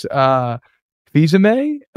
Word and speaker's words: Fils-Aimé: Mac Lindsay said, I believe it's pronounced Fils-Aimé. If it's anything like the former CEO of Nintendo America, Reggie Fils-Aimé.